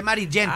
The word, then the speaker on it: Maritgen: M-A-R-I-T de tomate, G de gago, E-N. Yo lo pronuncié Maritgen.